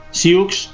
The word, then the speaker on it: Sioux: Entonces terminó llamándose Nadowesu, pero luego se contrajo esa palabra quedándose solo en Sioux, en inglés pronunciado Sioux. Sioux en inglés se pronuncia Sioux.